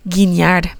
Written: guiñar

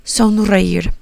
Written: sonreir

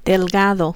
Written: delgado

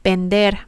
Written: vender

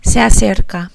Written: se acerca

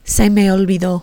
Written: se me olvidó

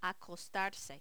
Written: acostarse

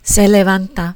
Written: se levanta

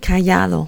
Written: callado

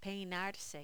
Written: peinarse

